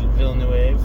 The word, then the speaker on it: Villeneuve: I always want to pronounce it as if it was Spanish, but I know he's French, and I know his first name is Denis, not Dennis. Villeneuve, and you gotta do that slight French accent on it to really bring your point home for a landing.